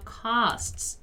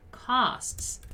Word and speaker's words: costs: Let's just pay attention here to the pronunciation of costs, okay? Costs, like this, costs.